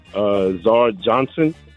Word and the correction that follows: Ezra Johnson: Oh, Ezra Johnson? – Ezra Johnson.